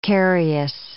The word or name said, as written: carious